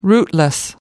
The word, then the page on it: rootless: American English